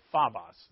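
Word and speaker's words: phobos (φόβος): And this, the Greek word is phobos. Or it's really phobos that they teach us the pronunciation, but where we get phobia from, it's fear.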